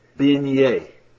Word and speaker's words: beignet: Let me introduce you to another Louisiana French word, beignet. It's spelled B-E-I-G-N-E-T. B-E-I-G-N-E-T.